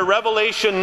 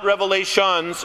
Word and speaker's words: Revelation: The book of Revelation, pronounced in the singular Revelation, not Revelations, is an amazing book of comfort and victory in our risen Lord and Savior Jesus Christ.